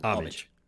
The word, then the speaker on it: homage: There's no H. – It's just an homage.